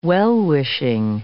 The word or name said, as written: well-wishing